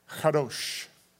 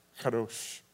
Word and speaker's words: Kadosh: Kadosh is how it's pronounced. Kadosh.